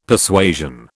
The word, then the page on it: Persuasion: British English